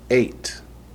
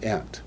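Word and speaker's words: eight (אֵת): Normally when you hear it read in Hebrew, they say et hashamayim ve'et ha'aretz, but it's not. It's eight, not et. What's the difference? When you find the Aleph Tav with the verb sere, which is the long A sound, it's referring to Messiah.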